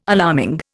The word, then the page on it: alarming: Indian